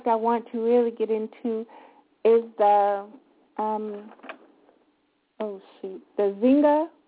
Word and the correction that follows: Ningxia: Ningxia Red.